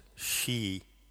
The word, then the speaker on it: sidhe: S-I-D-H-E, pronounced she. Very close to the Sanskrit word stri, S-T-R-I, meaning woman.